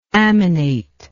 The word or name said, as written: amination